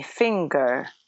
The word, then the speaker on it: finger: потому что в angel-то G, вот, и на этом плане возникают конфузы, но, ну, знаете, повторение мать учения, поэтому anger, а также begin и finger – все эти вещи произносятся как «г», как такая твердая русская «г» достаточно понятная и милая русскому уху.